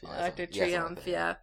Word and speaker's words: Arc de Triomphe: Because, I mean, Paris, France especially has such, like, iconic, like, you know, there's, like, the Eiffel Tower and, like, the, you know, Arch de Triomphe, or I'm saying it wrong. – Arc de Triomphe.